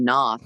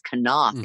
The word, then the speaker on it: Knopf: I was saying Knopf, and it's Knopf, apparently, which is way worse pronunciation, yeah.